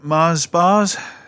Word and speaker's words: Mars bars: You mean Mars bars? – Oh, that's how you pronounce it, is it? – Yeah, it's quite a popular chocolate bar.